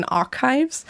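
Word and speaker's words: arXiv: Also da gibt es zum Beispiel einen, der wird einfach Archive ausgesprochen, es wird zum X dazwischen geschrieben und dann gibt es auch verschiedene, die heißen dann Med-Archive, Bio-Archive, Chem-Archive, Sog-Archive. – Archive wie Archiv. – Genau, wie Archiv, genau.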